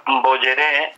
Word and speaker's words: Bojeré: que se pronuncia Bojeré, porque la J en guaraní se pronuncia como la Y. Se escribe en Bojeré y se pronuncia en Bojeré.